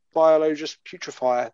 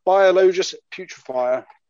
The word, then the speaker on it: Biologus Putrefier: So I've got two Mephistic Blight Haulers left, a Foul Blight Spawn, and a Biologus Putrefier. That is literally all I've got left to paint. – What was the last one? – A Biologus Putrefier. – You said it right twice.